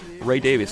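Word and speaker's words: Ray Davies: Ray Davis, that's the way he prefers to have his name pronounced.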